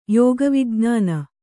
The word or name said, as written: ಯೋಗವಿಜ್ಞಾನ